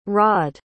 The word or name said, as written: raed